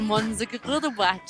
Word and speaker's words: Mönchengladbach: En el grupo B tenemos al Madrid, al al Inter y al Mönchengladbach.